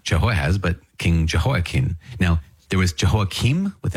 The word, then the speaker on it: Jehoiakim: I've also heard his name pronounced Jehoiachin with a kind of soft ch, Jehoiachin. But Jehoiakim is how I committed to saying it when we were reading 2 Kings and 2 Chronicles.